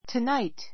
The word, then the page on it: tonight: tənáit; トゥナイト